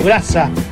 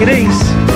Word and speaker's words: Grace: El otro día dijo un locutor dijo grasa. Es Grace. – Grasa, pero no se escribe grasa tampoco. – No. – Escribe Grace. – Grace. Y de pronunciarlo Grace, no grasa.